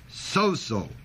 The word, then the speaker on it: sozo: The word saved is the word sozo. S-O-Z-O, long O.